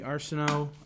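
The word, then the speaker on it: Arseneau: My name is Bernie Arsenault.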